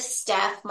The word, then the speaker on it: Stef: It's Wellness with Steph.